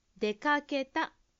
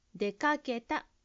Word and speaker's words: 出かけた: かけのところをしっかり発音するようにしましょう。出かけた、出かけたです。